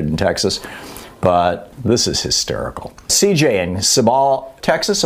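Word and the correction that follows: Cibolo: Am I saying that right, CJ? Thanks for calling. Thanks for listening to us on Sirius XM. What's up? – Hi. How you doing? This is Sibolo, Texas.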